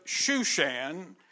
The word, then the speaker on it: Shushan: Most of the events take place in the city of Shushan, which is Hebrew, Susa is the Persian pronunciation.